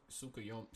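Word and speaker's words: Tsukuyomi: I thought it was like Tsukuyome. – You know what? I've never said that word like out loud. – Yeah, Y. It's a Y instead of an N, but in the dub they kind of pronounce it.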